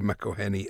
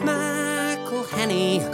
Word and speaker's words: McElhenney: It's McElhenney, McElhenney. What ways to massacre and mispronounce it, there are many. It's McElhenney, McElhenney. If there was doubt, now there won't be any. It's McIlhenny.